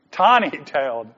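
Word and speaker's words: Taneytown: Tooneyville? Tawnytown. I knew it sounded like a cartoon. That's why I said Tooneyville. Tawnytown. I've never heard. How do you spell that? – T-A-N-E-Y.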